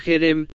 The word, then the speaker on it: Jerem: Ahora Jerem, se pronuncia Jerem, es una H dura, Jerem significa destrucción total.